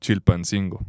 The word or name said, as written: Chilpancingo